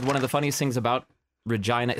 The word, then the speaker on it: Regina: It's pronounced Regina, like vagina.